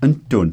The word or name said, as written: ntun